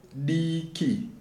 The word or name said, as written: Diki